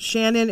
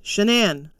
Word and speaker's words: Shanann: It's not Shannon, it's Shanann. So get it right.